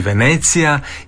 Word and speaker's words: Venecija: Pa ovako, mi Hrvati imamo, osim onog izvornog, Venecija, načina izgovaranja, kao što je u talijanskom jeziku, osim što je naglasak drugačiji, Venecija,